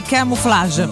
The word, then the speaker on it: Camouflage: Ridgeway Camouflage.